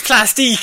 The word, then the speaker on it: Plastique: Plastic, I'm sorry. – Oh, for God's sake. No, it's Plastique. Plastic.